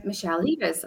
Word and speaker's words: Michelle Leivars: We are interviewing the wonderful, wonderful, oh, our followers, the wonderful Michelle Leivars.